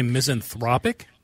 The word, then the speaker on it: misanthropic: Did you say misanthropic? – Yes. – Yep, that's the way you say that pronunciation, yes. Because it's misanthrope, but if it's kind of like economy and economic, it's kind of okay. Like that.